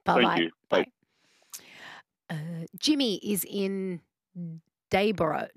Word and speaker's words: Dayboro: It's spelled O-R-O through me. – It is. – Yeah. It should be Debra, B-R-A-H. – Yeah. Everyone south of the border calls it Debra, but yeah, fair enough.